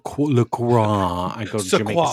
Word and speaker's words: LaCroix: Yes, and it's exactly, like, no, the LaCroix website literally says it rhymes with the term enjoy. So you can say enjoy LaCroix.